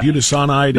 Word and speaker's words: budesonide: Bu, uh, bu, bu, budesonide or something like that. Yeah, budesonide. That's right.